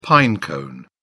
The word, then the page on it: pine cone: British English